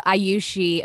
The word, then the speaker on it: Ayushi: So Ayushi, a name that is often pronounced as Shu or Sushi, actually means one who's blessed with a long life.